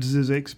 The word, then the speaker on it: Zzyzx: Every time we drive past it with Sean in the car, he always points out Zizek's because it's spelt Z-Z-Y-Z-X.